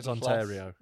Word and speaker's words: Ontario: That's not how it's said at all. It's Ontario.